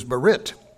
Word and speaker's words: berith: Whereas the Hebrew word for covenant is berit, B-E-R-I-T-H, the H is silent.